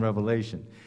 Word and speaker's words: Revelation: For example, a lot of people will pronounce the book of Revelation as the book of Revelations, as if there's an S on the end of it, but there's not.